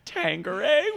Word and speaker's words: Tanqueray: Tanqueray, which I think is like the tango. – There's definitely not a G. – No, there's no G. It's a Q. – Tanqueray.